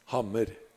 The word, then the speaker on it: hammer: Interestingly enough, the hammer is actually spelled exactly the same. – Oh. – But it's pronounced hamr instead of hammer.